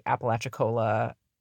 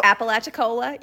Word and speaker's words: Apalachicola: This is a long-running dispute regarding the Apalachicola Chattahoochee, Flint.